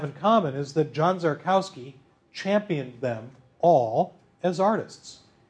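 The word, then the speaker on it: Szarkowski: Properly pronounced Czarkowski, Czarkowski, he was first and foremost a photographer.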